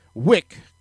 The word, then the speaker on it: Wyck: The original street and the freeway were both named after former New York City Mayor Robert Anderson Van Wyck, but the pronunciation of Wyck was heavily disputed depending on the person. Wyck could rhyme with either lick or like.